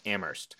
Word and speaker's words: Amherst: This is Amherst, not, you know, we're not talking Amherst. It's Amherst. All right, get it right. There's no H in the actual pronunciation.